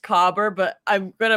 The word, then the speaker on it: caber: And the second thing is that it is spelled C-A-B-E-R, but the internet told me it's pronounced cobber, and if that's wrong, I'm so sorry.